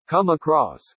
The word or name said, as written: come across